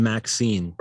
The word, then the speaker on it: MXene: They said it's pronounced Maxine. – Or like Maxine. – Like the female name Maxine.